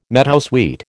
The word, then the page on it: meadowsweet: American English